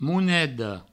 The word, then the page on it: moneda: mounedo